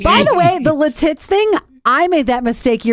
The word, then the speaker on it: Lititz: Oh, it's Lititz.